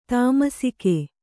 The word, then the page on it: ತಾಮಸಿಕ: tamasika